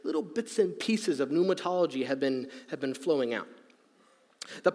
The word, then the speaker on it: Pneumatology: Pneumatology. Pneumatology. I know. I played you with the whole starts with a P thing. I did it on purpose. So, silent P. Pneuma. Pneuma.